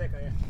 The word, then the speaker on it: Azekah: And again, Azekah, or is that how you say it? Azekah? – Azekah, yeah.